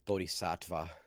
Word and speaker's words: Bodhisattva: Sorry, Bodhisattva.